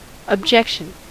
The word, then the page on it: objection: /əb.ˈdʒɛk.ʃən/